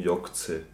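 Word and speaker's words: Yog'tze: Du hast mir einen Hint geschickt, auf dem sieht man ein Blatt Papier mit einer Schrift und da steht drauf, frei ausgesprochen, Jokze. Jok Apostroph C. – Perfekt also ausgesprochen. Also, Y-O-G genau. Apostroph T-Z-E.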